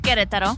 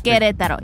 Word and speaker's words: Querétaro: Querétaro. – Querétaro? – Querétaro. You have to roll your R's.